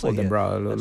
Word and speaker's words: Oldenburg: Dal festival di Oldenburg, qualcuno di commentare su Gonek ci parla della pronuncia, ma lo sentiamo, si dice Oldenburg, lo dice anche il nostro collega.